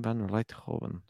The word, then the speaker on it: Rijthoven: I quite like Van Rijthoven. Is that how you say it? – Rijthoven, yeah.